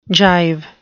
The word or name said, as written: gyve